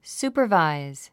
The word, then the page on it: supervise: súːpə r vàiz; スーパァバイズ